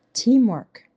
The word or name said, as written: Teamwork